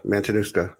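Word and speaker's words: Matanuska: Mantanuska? – I would say that's it right there, man. Mantanuska.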